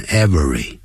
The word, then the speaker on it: every: Every year, 每一年呢，这个 every 变念成 every, 啊，也变念 every, 叫 every, 两个音节 every, every, every, every, 这样念。